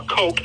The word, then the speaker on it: Koch: About guns, so it's a copy of a Heckler and Koch, or Coke as it's pronounced, Model 91 308 battle rifle.